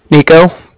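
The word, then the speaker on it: Niko: Nico. That's N-I-K-O.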